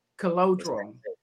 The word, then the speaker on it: Colangelo: Colangelo. – That's how you say it. – Colangelo. – It's Italian. I'm I'm tapping into my little my Italian side.